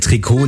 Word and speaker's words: Trikot: Jahrhundert setzte sich der Begriff für eng anliegende Sportkleidung durch, weil diese eben aus dem dehnbaren Strickstoff hergestellt wurde und weil im Französischen in der Regel die letzte Silbe betont wird, heißt es richtig Trikot.